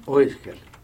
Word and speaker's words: Oesker: ¿Cómo se pronuncia el apellido? – Oesker. – Oesker, claro. ¿Qué es? ¿Alemán? – Alemán.